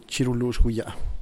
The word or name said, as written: txiruluxhguyaa